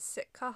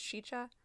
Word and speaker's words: Sica: It's S-I-C-A, but it doesn't mean it's pronounced in our... – Yeah. – In our phonetics. So, Sheecha Hollow State... That's so hard to say. – Sheecha? – Sheecha Hollow. – Sheeched or Sheecha? – Sheecha. – Sheecha? – Yeah, like S-H-E-C-H-A is like how you're supposed Sheecha. – To pronounce it.